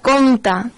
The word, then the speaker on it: compte: Llavors recordem que compte, tant compte com descompte, en aquest cas quan parlem de números, s'escriuen amb MPT D'acord? Tot i que aquesta P no l'acabem de pronunciar.